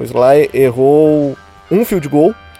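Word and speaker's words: Slye: Como é que fala o nome dele? Vocês sabem? – Sly, é Sly mesmo.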